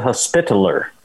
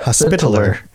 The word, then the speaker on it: Hospitaller: As, as the Hospitaller is how you pronounce that. – Hospitaller. – The Hospitaller.